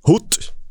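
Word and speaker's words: Hoedt: Eh, Wesley Hoot som han ju faktiskt uttalas, även om man skulle vilja få in ett eh, ö av eh, oet där.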